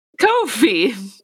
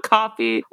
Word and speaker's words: Ko-fi: Shrimpocrits is now on Ko-fi, which we discovered is pronounced Ko-fi and not coffee or Ko-fi.